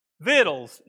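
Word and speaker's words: victuals: The reason I say that, if you've got a King James Version, in verse 11, Joshua says, gather up your, and here's how it's spelled, V-I-C-T-U-A-L-S. Y'all know what that word, you know how to pronounce that word? Vittles. Isn't that funny? Joshua says, gather up some vittles. That's a good East Texas term, isn't it? Gather up your vittles.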